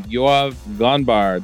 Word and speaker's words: Yoav Ganbar: I'm Charles Max Wood from Top End Devs, and this week we're going to be talking to Yoav Ganbar.